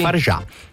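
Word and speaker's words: Fargeat: Va bene, signore e signori, parliamo di un film molto bello di Coralie Fargetta. Com'è che si pronuncia qui? – Farjà. – Farjà? Eh? – Sì, Coralie Farjà.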